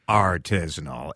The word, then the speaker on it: artisanal: And she was making just like, and you say artisanal. – Yeah. – Like it is artisanal. It's emphasis on the art.